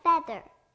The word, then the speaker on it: feather: 자, 이제 EA, 에 소리가 나요. 자, 이제 세 번 발음해 주세요. – Feather.